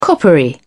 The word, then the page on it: coppery: British English